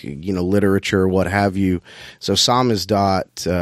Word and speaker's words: Samizdat: Samizdat. Thank you for pronouncing that for me.